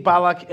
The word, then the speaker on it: Balak: Do you remember the biblical story of Balak, King Balak and Bilam? Do I pronounce that in Christianese correctly? Balaam. All right. So if you recall, what about Balak? How's he pronounced? – Balak.